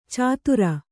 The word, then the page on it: ಛಾದರು: Kannada